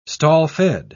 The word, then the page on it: stall-fed: British English